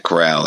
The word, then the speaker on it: Corral: Corral? – Matt Corral, like Golden Corral. – Yeah, there you go. He went there at the 210. I would assume Pickett would be more around your area and Corral in the third, but I mean, what do I know? – Yeah.